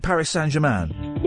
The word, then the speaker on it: Paris Saint-Germain: Paris Saint-Germain weren't... Is that how you say it? Paris Saint-Germain? – Yeah, or PSG.